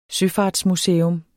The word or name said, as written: søfartsmuseum